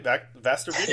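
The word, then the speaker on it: Västervik: Yeah, and I think you mentioned that you were going to be heading is it Vastervik? Am I saying that right? Vastervik? – Yeah, exactly.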